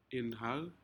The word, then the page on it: inhal: Aneityum